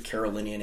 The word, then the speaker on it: Carolingian: So upon the death of Charlemagne and his son later on, the empire that he does establish as the Carolingian Empire, probably mispronouncing that. – No, you have it right.